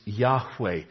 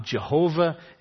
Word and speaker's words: Yahweh: God's name is Yahweh. It's not Jehovah. It's not Elohim. You've heard it pronounced Jehovah because of the influence of the German language on American. But in the Hebrew language, there is no J in their alphabet, and there is no V.